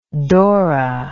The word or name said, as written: dora